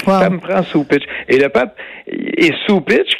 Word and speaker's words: Cupich: C'est C-U-P-I-C-H, mais on prononce Soupitch.